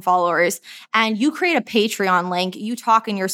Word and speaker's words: Patreon: So something that I'm loving is Patreon. Am I saying that right? – Patreon.